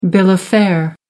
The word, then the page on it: bill of fare: American English